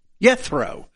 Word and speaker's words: Jethro: Jethro would be the way it would have been pronounced.